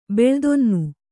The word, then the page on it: ಬೆಳ್ದೊನ್ನು: Kannada